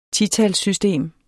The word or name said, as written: titalssystem